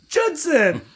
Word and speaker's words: Judson: his name rhymes with Hudson. – Judson. There you go.